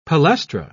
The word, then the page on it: palaestra: British English